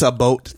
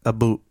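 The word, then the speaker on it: about: Did I say that right? No, I didn't. Not what? A boat time. It's a boot. How do they say it? – A boot, yeah. – A boot time.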